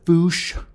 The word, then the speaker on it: Fuchs: Og hun er allieret med Erica Fuchs, eller Fuchs, skal det udtales, som er biolog, fordi de går især efter DNA'en.